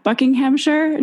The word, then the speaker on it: Buckinghamshire: And before that, I think you were a senior lecturer and leader of two music business programs at Buckinghamshire New University.